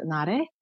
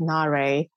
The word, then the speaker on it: Na-Rae: In Korean, my name is pronounced Na-Rae.